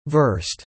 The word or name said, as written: versed